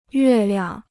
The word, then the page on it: 月亮: yuè liang